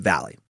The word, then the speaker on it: Valle: If you watch enough videos, sometimes he'll see his or hear his name pronounced like Valle or Valle. Gil himself pronounces it Valley. So that's why I'm going with that.